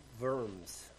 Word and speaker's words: Worms: He was asked, and I know I'm going to mispronounce this, at the Diet of Worms, I think's how it's said. It looks like the Diet of Worms, but I know that's not how it's pronounced.